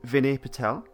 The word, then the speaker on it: Vinay Patel: so this episode was written by Vinay Patel.